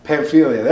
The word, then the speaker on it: Pamphylia: Mom, you want to tell me how to say it? – I don't have a Bible. – Pamphylia. – There you go.